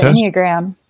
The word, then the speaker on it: Enneagram: The Enneagram. – Yeah. The Enneagram.